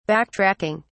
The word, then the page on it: backtracking: British English